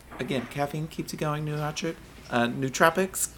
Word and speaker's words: nootropics: How do you say nootropics? – No, nootropics. Yep, nootropics. You said it right.